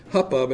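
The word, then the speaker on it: chuppah: It's actually spelled H-U-P-P-A-H. It looks like Huppah, but they pronounce it Huppah.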